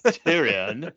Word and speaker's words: Tyrion: where Tyrion says, that's how it's pronounced, Tyrion.